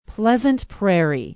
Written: Pleasant Prairie

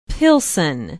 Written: pilsen

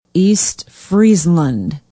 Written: east friesland

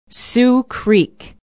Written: sioux creek

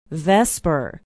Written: vesper

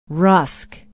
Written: rusk

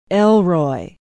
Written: elroy

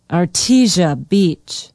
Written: artesia beach

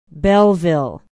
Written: belleville

